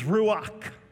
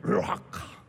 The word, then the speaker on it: ruach: Now, the Old Testament was written in both Hebrew and Aramaic, so the Hebrew word for breathe is ruach, and in order to say it correctly, you gotta have a little bit of something in your throat. Ruach.